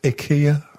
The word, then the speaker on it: IKEA: And IKEA is apparently supposed to be pronounced IKEA.